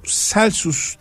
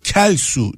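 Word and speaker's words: Celsus: Şimdi e, ben bunu Celsius diye e, bir telaffuz ettiğimde sosyal medyadan bir dinleyicim demişti ki bu binlerce yıldır bu şekilde telaffuz ediliyor ve yanlış telaffuz ediliyor. Bunun doğrusu Kelsu diye okunurmuş efendim. Bunu da bu vesileyle söyleyelim.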